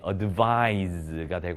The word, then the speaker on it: advise: 지금 여기 앞에 있는 se로 끝나는 것은요. 발음이 advise가 되고요. 이 발음으로 끝납니다. advise. 뒤에 있는 c로 끝나는 것은 advice. 이 발음으로 끝나게 되겠죠.